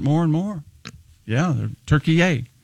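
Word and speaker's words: Türkiye: So he immediately demanded that it be pronounced Turkiye. Turkiye. Turkiye.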